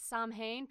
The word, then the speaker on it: Samhain: If you look it up, it looks like it's pronounced as Samhain because that's how it's spelled, but it's actually pronounced Samhain.